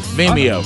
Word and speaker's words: Vimeo: It's on you say Vimeo? Is that how you say it? – Yeah. – It's Vimeo.